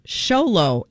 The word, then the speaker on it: Xolo: It looks like Zolo Maraduena, but it's Xolo. X-O-L-O is Xolo Maraduena.